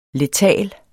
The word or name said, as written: letal